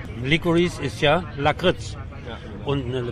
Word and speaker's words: Licorice: Der Sprecher vom Deutschlandradio hat den Film betitelt als Licorice Pizza, also hat sozusagen die italienische Aussprache gewählt. Ja. – Dann gab es eine Kritik von Katja Nikodemis, glaube ich, von der Zeit, wo ich gehört habe, die hat gesagt, Licorice, also so die amerikanische Aussprache.